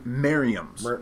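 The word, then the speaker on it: Merriam's: But the ones we have to worry about here are the Merrimans, and did I say that correctly? The Merrimans? – Merriams.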